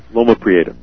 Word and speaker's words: Loma Prieta: I've documented since the, am I saying this right? The Promo Eleta, is that the word? The earthquake in 1989 up there in San Francisco. – Loma Prieta, right.